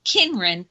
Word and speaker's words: Cynren: And then, of course, you could also purchase it through my publisher, and they are in at CYNREN, and we pronounce it Kinren Press in Malvern, PA, and they also have my book there.